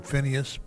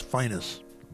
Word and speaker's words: Phineas: Some people call him Phineas, but the Memphis pronunciation of his name is Phineas. Phineas